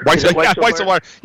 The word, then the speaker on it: Weisselberg: Weisselberg.